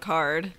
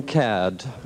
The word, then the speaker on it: Caird: And he got John mad Caird. That they mispronounced his name at the Tonys. They called him John Card. And he got up and was like, actually, it's Caird. John Caird.